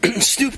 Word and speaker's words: Snoop: But it's okay for Snoop Dogg. His name Snoop rhymes with stoop.